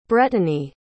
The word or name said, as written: brettany